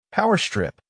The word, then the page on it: power strip: American English